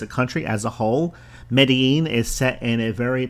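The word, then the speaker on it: Medellín: It's spelled M-E-D-E-L-L-I-N. I guess if you just spelled or pronounced it phonetically, it would sound like Medellin, but with the accent, it's Medellin.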